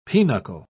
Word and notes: pinocle: British English

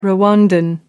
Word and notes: rwandan: American English

